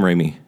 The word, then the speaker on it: Raimi: Sam Raimi.